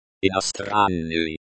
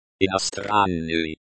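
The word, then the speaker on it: иностранный: Be sure to pronounce the Y sound at the end of inostranny, as in sky or my.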